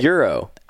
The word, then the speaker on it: gyro: G-Y-R-O. – Gyro. – It's not gyro. – I probably only eaten two. – Gyro. – What is it? – Euro. – Oh, gyro. Oh, the G is silent. – But some people say gyro, which I don't think is right. – Oh. – I think it's euro.